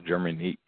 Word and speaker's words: Jermanic: Are we pronouncing your name right? Jermanic. It's Jermanic, correct? – It's pronounced Jermanic.